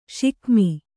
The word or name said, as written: ಷಿಕಮಿ